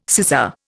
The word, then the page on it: sizar: Indian